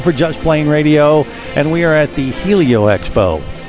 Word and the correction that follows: Heli Expo: Did I say it right? – No, as usual, you screwed it up. – Well, what did he say? – It's the Heli Expo.